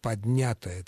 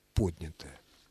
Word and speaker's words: поднятая: Поэтому, конечно же, «поднятая целина» исключено категорически. Правильное ударение – поднятое.